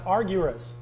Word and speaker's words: argyros: And so you have a, philo, and then you have a Greek word that's pronounced argyros.